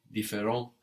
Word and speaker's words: différent: No, différent has an S on the end, but we don't hear it. T and S are one consonant syllable. No, and that's silent on the end there. So the singular and the plural sound the same.